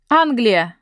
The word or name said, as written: England